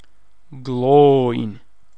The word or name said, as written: Gloin